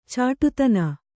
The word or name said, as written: ಚಿಟ್ಟೆತಾನ